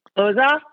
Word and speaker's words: Lozoff: How do you pronounce Lozoff? – Lozoff, Bo Lozoff.